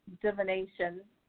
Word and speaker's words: divination: Bird bones have also been used in divination.